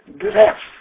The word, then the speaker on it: Drews: The name, pronounced in Polish, was Dress. And it was spelled D-R-E-W-S. – D-R-E-W-S.